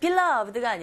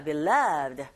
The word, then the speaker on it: beloved: 이 beloved 같은 경우에는 beloved가 아니라 beloved. 두 번째 위치에 강세가 있기 때문에 this와 쭉 한 덩어리처럼 this beloved 이렇게 들렸거든요. 여러분들도 발음했을 때 그렇게 구사하시면 됩니다.